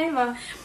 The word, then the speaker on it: Ewa: Yo pensé que se llama Eva, pero se escribe como Ewa. – Claro, se escribe E-W-A, Roca con K, Tattoo.